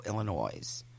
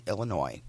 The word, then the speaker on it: Illinois: She grew up in the neighboring communities of Highland Park and Glencoe, Illinois. If you're not from, you know, the S is silent.